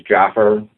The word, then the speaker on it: Jaafar: Again, my name is Jaffer. It's spelled J-A-A-F-A-R.